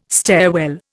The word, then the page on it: stairwell: Indian